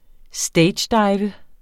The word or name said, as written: stagedive